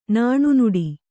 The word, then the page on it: ನಾಣ್ನುಡಿ: nāṇnuḍi